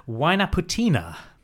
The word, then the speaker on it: Huaynaputina: And specifically, we are going to go to the volcano Huaynaputina.